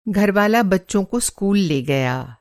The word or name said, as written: घरवाला